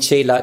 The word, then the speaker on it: Chela: Ich hätte es Chela ausgesprochen. – Genau, weil ich nenne es immer, ich nenne es immer Shishya. Das ist einfach das gleiche Bedeutung. Und Chela, genau, hast du wunderbar richtig ausgesprochen.